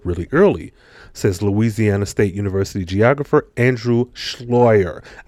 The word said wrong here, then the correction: Sluyter: I know I said that wrong. Andrew S-L-U-Y-T-E-R, Slider, excuse me, who was not involved in the research.